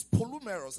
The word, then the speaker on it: polumeros: The Greek word for sundry times there is polumeros. I'll spell that for you. P-O-L-U-M-E-R-O-S. P-O-L-U-M-E-R-O-S.